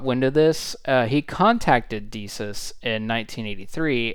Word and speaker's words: DECUS: The game was submitted to DECUS, I believe it's pronounced. It's D-E-C-U-S, DECUS, which its programs often were a part of the initial games installed on DEC computers.